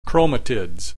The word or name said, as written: chromatids